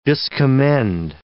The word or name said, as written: discommend